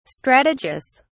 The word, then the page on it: strategus: British English